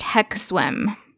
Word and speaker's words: HXWZM: And it's spelled HXWZM, pronounced Hex Swim.